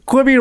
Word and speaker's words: Quibi: Quibi rhymes with Libby.